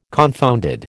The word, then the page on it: confounded: American English